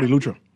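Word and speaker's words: Eleuthera: We got South Central, how you say it? South Elutra? – South Central and South Elutra.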